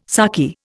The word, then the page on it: sarky: Indian